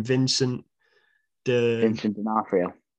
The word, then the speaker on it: Vincent D'Onofrio: Especially if, how do you say his name, Vincent D'Onofrio.